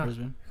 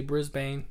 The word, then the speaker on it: Brisbane: Brisbane. – Props for pronouncing it right. – Oh, yeah. – Most gringos say Brisbane.